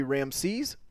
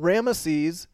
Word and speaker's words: Ramesses: The more common way to pronounce it used to be Ramses, but Ramesses is gaining in acceptability. The battle continues.